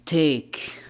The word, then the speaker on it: take: قلنا حرف الـ E ينطق حرف الـ E كما هو، وبعض الأحيان يكتب ولا ينطق. متى؟ إذا أتى في نهاية الكلمة إن لم يتكرر، مثل كلمة take، الإي يكتب ولا ينطق.